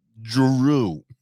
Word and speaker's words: Jrue: Drew spelled like a moron though. Drew. J-R-U-E.